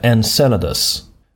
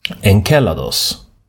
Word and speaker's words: Enceladus: På engelska så alltså säger man Enceladus med ett S-ljud. Men det grekiska ordet, när månen är namngiven efter en av giganterna i den grekiska mytologin. Och där är jag ganska säker på att ordet ska uttalas Enceladus. Med K, det skrivs med kappa.